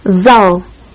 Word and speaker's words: зал: The first letter of this next word looks like our number 3 and is pronounced like an English Z. – Зал.